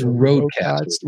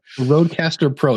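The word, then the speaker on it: RØDECaster Pro: Rode, the Procaster, is it? No. – It's Rodecaster. – Rodecaster. – Rodecaster Pro.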